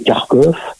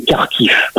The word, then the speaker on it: Kharkiv: Kiev, hein, je précise à chaque fois il y a les deux orthographes, hein, faut pas oublier que l'orthographe russe fait prononcer Lvov, Kiev et Kharkov, et donc en ukrainien c'est bien Lviv, Kiev et Kharkiv.